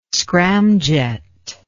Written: scramjet

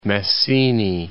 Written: Messini

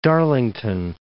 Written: Darlington

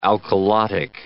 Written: alkalotic

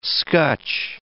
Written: scutch

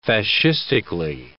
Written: fascistically

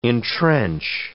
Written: entrench

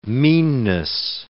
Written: meanness

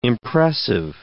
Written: impressive

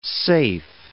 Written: safe

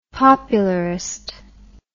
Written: popularist

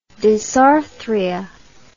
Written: disarthria

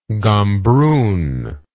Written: gombroon

